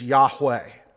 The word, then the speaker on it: Yahweh: In the Hebrew language, we are simply given four consonants that correspond with our consonants, YHWH. And we speculate, we're pretty sure, that the pronunciation then is Yahweh.